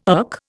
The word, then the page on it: irk: Indian